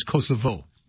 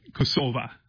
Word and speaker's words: Kosova: And, teach us all how to properly pronounce what we've been pronouncing as Kosovo. – Right, Kosova.